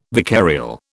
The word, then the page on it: vicarial: British English